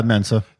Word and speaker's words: Mensa: Yeah, Mensa.